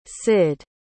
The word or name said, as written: cyd